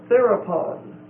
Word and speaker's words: therapon: The first word is therapon. Therapon. T-H-E-R-A-P-O-N. It's a long O. T-H-E-R-A-P-O-N.